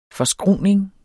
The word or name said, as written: forskruning